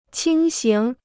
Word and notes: 轻型: qīng xíng

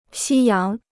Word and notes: 夕阳: xī yáng

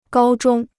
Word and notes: 高中: gāo zhōng

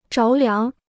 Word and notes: 着凉: zháo liáng

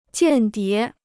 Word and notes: 间谍: jiàn dié

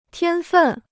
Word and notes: 天分: tiān fèn

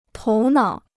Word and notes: 头脑: tóu nǎo

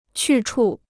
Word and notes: 去处: qù chù

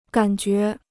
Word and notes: 感觉: gǎn jué

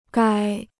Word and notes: 该: gāi

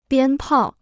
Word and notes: 鞭炮: biān pào

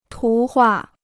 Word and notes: 图画: tú huà

